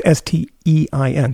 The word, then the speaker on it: Stein: Well, it's S T E I N, so it is Stein.